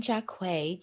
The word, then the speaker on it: Jaquai: So as mentioned, I'm Jaquai, just two syllables, a Q.